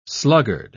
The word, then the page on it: sluggard: British English